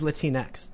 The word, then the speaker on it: Latinx: She's Latinx, though. – Latinx. – Latinx. – Like Kleenex, yeah. – Yeah, like Kleenex.